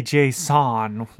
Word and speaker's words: Jason: Just like the markup language that you might use to pass data to a server. – It's pronounced that way. – Well, you kind of pronounce it that way. – I say Jason. – But you kind of said it slow. You slow rolled your name. – I say Jason. The O is what's different.